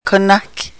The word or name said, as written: konahke